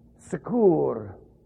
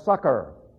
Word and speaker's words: succor: Now, the King James uses a very strange word, secour, which is actually pronounced succor.